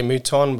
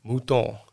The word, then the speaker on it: Mouton: In English we'll say Mouton, but it's really a French name. So however you're going to say it in English is going to be wrong anyway. So. – But what is it? – Mouton. – Mouton. – Yeah, that's how you say it in French.